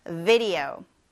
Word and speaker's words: video: video. video. video. 네, V로 시작하니까 V 이렇게 이를 아랫입술에 살짝 갖다 대면서 V 발음하는 거 잊지 마세요.